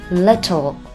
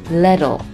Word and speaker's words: little: 这个单词在美式英语当中既可以把字母 T 发成 T, little, 也可以把字母 T 发成接近于字母 D 的发音，就是这样。 little, little, 可能更习惯于后一种念法，但是大家可以选择自己习惯的方式。